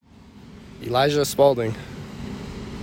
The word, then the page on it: Elijah Spaulding: e LIE zhah SPALL ding